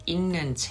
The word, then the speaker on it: 읽는: 네, 읽는 책. 네, 이거 발음 좀 조심해야 돼요. 읽는이에요.